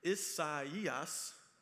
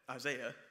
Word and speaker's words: Isaias: And the Monday night that we were there, Hurricane, see if I can get the pronunciation right, Isaias came. I kept calling it Isaiah because that's what it looks like.